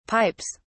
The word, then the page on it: pipes: British English